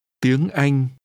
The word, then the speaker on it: Tiếng Anh: Tiếng Anh. Tiếng. – Language is pronounced with a high rising tone.